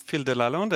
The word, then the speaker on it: Phil Delalande: Well, I'm Phil Delalande, and you pronounce my name perfectly, which is a rare thing.